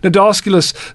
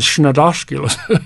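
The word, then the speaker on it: Cnidoscolus: Nodosculus starts with a silent C, by the way, not an N, so it's C-N. I was gently corrected years ago by a good friend when he heard me saying Schnodosculus.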